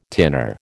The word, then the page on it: tinner: American English